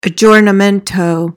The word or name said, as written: aggiornamento